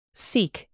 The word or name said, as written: Sikh